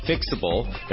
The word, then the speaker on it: Phyxable: We're chatting with Dr. Jimmy Fang, co-founder and CEO of Fixable, that's spelled P-H-Y-X-A-B-L-E.